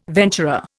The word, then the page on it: venturer: Indian